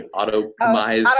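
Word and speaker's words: Autoptimize: Auto-topomize, I can never pronounce that plugin properly. Auto-optimize, that's it.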